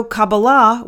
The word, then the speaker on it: Kabbalah: Kabbalah, which is Jewish mysticism. As I always say, I am a mystic. I am not necessarily a religious person. I am a mystic. And so Kabbalah,